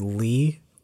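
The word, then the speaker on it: Lei: My Instagram is Lee White, and Lee is spelled a little weird.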